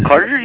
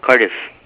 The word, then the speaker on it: Cardiff: Cardiff, it sounds like Carter, but I'll spell it real quick. I know it sounds funny. It's C-A-R-D like David. – Uh-huh. – I, F-F like Frank.